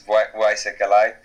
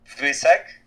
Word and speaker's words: Wysek: And, like, if you look to pronounce my name, it's, like, either Wysek, it's the Polish one, or Wysek if it comes for English people and how they want to pronounce that.